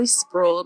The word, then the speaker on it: Spruill: So you can find me on Facebook, Kimberly Spruill. My last name is spelled S as in Sam, P as in Paul, R-U-I-L-L.